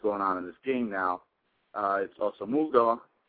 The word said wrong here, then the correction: Asomugha: I believe that's how you pronounce him, uh, his name, uh, who defended Asomugha. – uh, Asomugha, uh, who defended go.